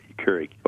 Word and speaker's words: Keurig: And the great thing about this, I'm probably mispronouncing it, the Keurig or whatever. – A Keurig? – Keurig, okay.